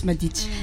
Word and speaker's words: Madicha: Juste le A, il s'entend pas. Voilà. Jess Madicha.